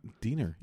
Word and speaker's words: Diener: Wife is Joan Diener or Diner. – I think it's D-I-E-N-R. Oh, I got it wrong. – N-E-R. Diner. Diener. – Diener.